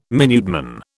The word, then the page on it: Minuteman: British English